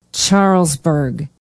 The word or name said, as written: charlesburg